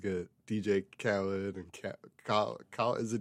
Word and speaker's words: Khaled: Apparently he tweeted and he said, Khalid, quote, is pronounced Khalid. So it is Khalid. – Khalid. Okay. Mm-hmm. All right. Yeah, I need to get that through my head. Khalid. And then DJ Khaled is Khaled.